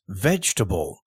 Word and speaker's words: vegetable: Or in English, V-E-G-E-T-A-B-L-E. So just like comfortable, it has the word table in it, but it is not pronounced like table. We don't say vegetable, we don't say vegetable. We say vegetable, vegetable.